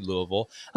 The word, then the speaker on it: Louisville: I said that right, actually, Louisville.